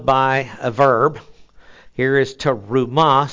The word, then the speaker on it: terumah: Now, the word for heave offering is terumah. T, the short E, R-U-M-A-H, terumah.